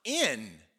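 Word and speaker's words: in (ἐν): And then all of a sudden, the Holy Spirit is in, and actually the Greek word is en, and it's literally pronounced in, right?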